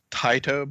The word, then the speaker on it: Taito: And it's pronounced Taito, by the way. – Oh, but, Taito? I didn't know that. I always thought it was Taito.